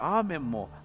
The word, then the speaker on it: アーメン: そしていよいよこのアーメンに入る。アーメンも発音のしようがないようなものですけども、アーメン、最後にメンヌ、ヌがつくような発音の仕方です。